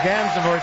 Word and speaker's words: Gansevoort: Morning Sedition live at the Cat Restaurant Florent here on Gansevoort Street. Gansevoort Street. I say it right the whole day. Screwed up at the end.